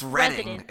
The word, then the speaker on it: Reading: It's pronounced Reading.